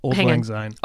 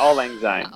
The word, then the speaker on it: Auld Lang Syne: Oh, Auld Lang Syne. – Oh, thank you. What is it? – Oh, yeah, Auld Lang Syne.